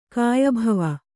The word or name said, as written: ಕಾಯಭವ